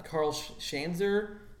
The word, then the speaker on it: Karl Schanzer: What's funny, I, I, I feel maybe Jack Hill must have directed that guy because that actor, uh, Karl Schanzer, that's how you say it, was in the Jack Hill movie Spider Baby.